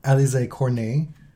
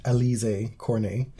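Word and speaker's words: Alizé Cornet: One to Alize Cornet. Not pronounced Alize Cornet. – For some reason, all of the Brits have formed a consensus around the incorrect pronunciation of her name.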